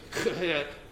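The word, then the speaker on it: Chet: It's almost like you pronounce it with a, you clear your throat. It's a Kehet.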